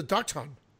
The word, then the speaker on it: Dachshund: I guess the correct pronunciation is a Dachshund.